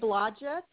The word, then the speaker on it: Blodgett: It's Renee Blodgett and Anthony Blodgett.